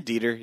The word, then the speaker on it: Dieter: Dieter, is that how you pronounce his name? The German guy? – Oh, yeah, Dieter, yeah.